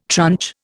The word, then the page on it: tranche: Indian